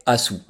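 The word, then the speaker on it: aszú: On les appelle les baies Asu, Alors ça s'écrit A-S-Z-U.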